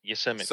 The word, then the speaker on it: Yosemite: Yes, Yosemite.